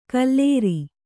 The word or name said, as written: ಕುಲೀರ